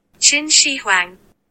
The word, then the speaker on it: Qin Shi Huang: Huang. There's no W. There's absolutely no W.